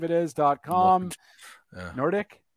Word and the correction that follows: Nordic: No, it's just the way you said it. You emphasized the dick part. – The dick? – Yeah. – Nordic.